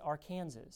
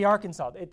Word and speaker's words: Arkansas: So, in Arkansas, you're not allowed to pronounce Arkansas incorrectly. You can't pronounce it Arkansas. You guys know how you spell Arkansas, right? Arkansas, okay? It's got to be Arkansas.